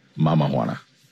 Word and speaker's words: Mama Juana: Is it pronounced Mama Juana or Mama Juana? – Mama Juana.